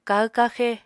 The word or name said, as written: kahw-kaxe